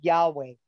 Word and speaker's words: Yahweh: They know that those letters are pronounced Yahweh.